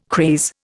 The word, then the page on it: craze: Indian